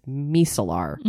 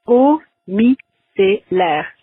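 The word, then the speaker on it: eau micellaire: It's eau-mi-c-l-air. So the O sound in French is kind of like the O in a word like cold. It's eau, eau.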